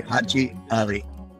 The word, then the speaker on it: Haji Ali: And didn't roll off the American's tongue very easily, so when they tried to pronounce it, it sounded like Hajali. And so that's what he became. But his name is really Haji Ali.